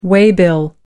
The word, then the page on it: waybill: American English